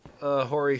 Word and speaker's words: Hori: I recommend Hori Fight Stick. Hori's Fight Stick? – Hori. – How do you spell it? – H-O-R-I.